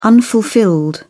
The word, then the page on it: unfulfilled: British English